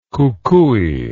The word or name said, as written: kukui